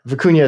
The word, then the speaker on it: Vicuña: So it was, yeah, Vicuña is the correct pronunciations.